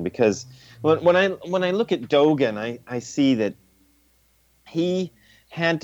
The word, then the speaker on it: Dogen: And Dogen, is that the way you say his name? – Dogen. – Dogen, thank you.